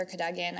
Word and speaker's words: Cadogan: I pronounce it Sir Cadogan. I've heard other people pronounce it different ways. That's how Jim Dale pronounces it in the audiobooks, and so I always side with Jim Dale.